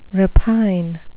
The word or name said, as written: repine